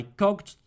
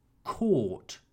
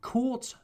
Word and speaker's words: caught: Catch in the simple past tense is pronounced caught. I caught the bus.